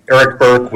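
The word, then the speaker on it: Erik Burke: Mr. Chairman, members of the Appropriations Committee, Eric Burke with Montana Federation of Public Employees. Name is spelled E-R-I-K, last name B-U-R-K-E.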